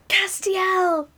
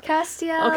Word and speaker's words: Castiel: So Castile... – Castiel! – Oh, Castiel. Oh, so I was saying it wrong. My bad. Yikes. – Castiel.